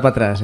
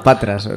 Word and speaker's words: Patras: No, no era patrás, era ¿no? – patras.